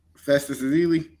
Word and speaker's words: Festus Ezeli: Festus Ezeli.